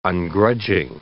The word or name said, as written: ungrudging